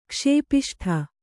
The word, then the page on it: ಕುಶಪಿಷ್ಟೆ: kuśapiṣṭe